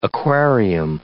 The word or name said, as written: aquarium